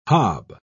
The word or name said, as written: hob